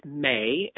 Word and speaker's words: Mei: So on Facebook, my name is Alex May, and that is spelled M-E-I.